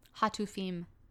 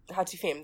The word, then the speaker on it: Hatufim: it's like Hatufim or something like that. – I think it's Hatufim. – Hatufim, there you go.